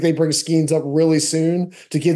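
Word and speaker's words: Skenes: Is Skeens the pronunciation? – Skeens.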